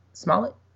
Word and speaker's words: Smollett: Is that how you say it? Smollett? – Yeah.